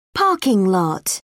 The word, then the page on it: parking lot: British English